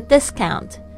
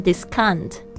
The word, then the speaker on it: discount: 特别注意一下这个 discount 哦，不要念成 discount，那个 o u 发。嗷的声音啊，这个是我们很多同学会犯的错误啊。